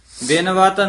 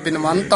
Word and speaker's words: बिनवत: किशु ना जाना मत मेरी थोरी बिनवत नानक ओट प्रभ तोरी। – हाँ, ज्यादा बिनवंत आउंदा है इत्थे बिनवत है ध्यान नाल उच्चारण करना है।